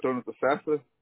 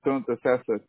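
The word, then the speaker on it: Thonotosassa: Okay, do you have a Dontasasa? – Which one? – Dontasasa. – Spell that one for me. – T-H, everybody have a problem pronouncing. It's T-H-O-N-O-T-O-S-A-S-S-A.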